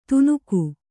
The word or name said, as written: ತುನುಕು